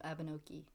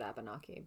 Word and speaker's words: Abenaki: I think it's Abenaki. – I think everyone I heard pronounce it said Abenaki, but...